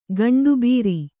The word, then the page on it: ಗಂಡುಬೀರಿ: gaṇḍu bīri